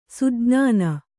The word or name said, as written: ಸುಜ್ಞಾನ